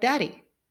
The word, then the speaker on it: DADI: Therefore, we created the DADY acronym, D-A-D-I, not Y. When pronounced, it sounds like DADY, which was memorable for students.